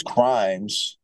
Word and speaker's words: Crimes: And then a good friend of mine, his name is Crimes, it's like Rhymes with a C, he did a remix on the song that you played, Hate on the Rocks, and put his whole spin to it.